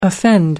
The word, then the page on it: offend: American English